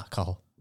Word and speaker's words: Cathal: Is that the C-A-T-H-A-L? – Yeah, Cahill. How you pronounce it? – I thought it was Cathal. – Nah, Cahill.